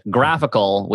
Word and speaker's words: GraphQL: So for those who, you know, who can't see my screen at the moment, the normal GraphQL, so it's GraphQL. Pronounced graphical, which is super confusing in audio form because it's a graphical GraphQL. It's an adorable pun.